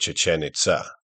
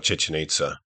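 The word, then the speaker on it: Chichén Itzá: The lake is named Petén Itzá, because the Itzá people, the one whose ancestors had built Chichen Itzá, or Chichen Itza, as it's normally pronounced in the West, moved there and made it their new capital.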